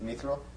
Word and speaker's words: mithril: Mithril.